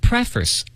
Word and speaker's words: preface: 对，朋友们，这个词啊，看着好像挺简单的，pre 加上一个脸部的这个 face 是吧？ – 嗯。 – 但它发音不一样，它要读成 preface。